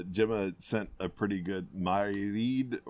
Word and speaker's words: Mairead: anyway, so I think it's Mairead, like Mairead, like almost like Maureen, but Mairead.